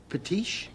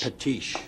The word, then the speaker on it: patiche: How do you say it again? – Patiche. – Patiche. – Patiche. – Patiche. – That's right. – Patiche.